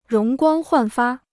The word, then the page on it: 容光焕发: róng guāng huàn fā